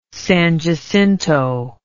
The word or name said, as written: San Jacinto